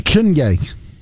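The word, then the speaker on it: Chiney: Is it Chiny Okawaikmike? – Chinye. Chinye.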